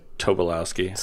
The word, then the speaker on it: Tobolowsky: Tobolowsky.